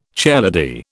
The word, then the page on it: chairlady: British English